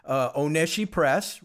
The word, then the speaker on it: Oneshi Press: And we should say it's Oneshi Press, right? I got the pronunciation correct again. – Perfect. – Yes, you did.